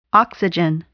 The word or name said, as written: oxygen